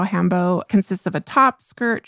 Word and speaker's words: hanbok: I usually say hanbok because it's H-A-N-B-O-K. And so it's pronounced hanbo.